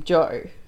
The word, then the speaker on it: Jo: And I said, okay, to pronounce my name, Joe, you just do a J sound and then all the vowels in order.